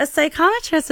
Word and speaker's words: psychometrist: And one of your titles is psychometrist.